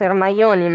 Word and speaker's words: Hermione: che alcuni dicono che effettivamente andrebbe pronunciato Ermaioni, ma la verità è che no, no ragazzi.